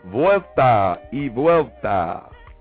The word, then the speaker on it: vuelta y vuelta: V-U-E-L-T-A. Y, which is pronounced E. You know what I'm saying? Vuelta y vuelta. V-U-E-L-T-A. E-V-U-E-L-T-A.